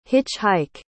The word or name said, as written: hitch-hike